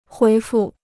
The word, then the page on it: 回复: huí fù